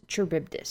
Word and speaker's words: Charybdis: Okay, the computer says it's Charybdis, so that's what we're gonna go with.